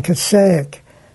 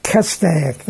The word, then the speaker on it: Castaic: It's in Castaic. – Castaic, that's right.